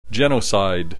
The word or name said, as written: genocide